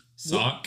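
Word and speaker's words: Schoch: I don't know how you say his name. Sock? – What? Yeah, I was going to say, can we just...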